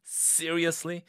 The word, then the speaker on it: seriously: 아, 나 정말 진짜 이런 짜증나는 상황에서 seriously, seriously, S를 C, 이 seriously 할때그 S 발음을 좀 강하게 하셔야 돼요.